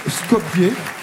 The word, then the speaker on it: Skopje: Skopje, je crois qu'on prononce Skopje. Ça s'écrit S-K-O-P-J-E. Et je crois que la prononciation, sauf erreur de ma part, c'est Skopje.